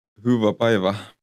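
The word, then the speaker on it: hyvää päivää: Huva beiva. – Der er også, det en norsk y, men det uttales som en u. – Mm. – Og den A-en med tødla uttales som en æ.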